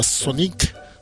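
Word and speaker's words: Sonique: es uno que se llamaba Sonic, que se escribe Sonic con Q. Sonic, Q-U-E. Sonic.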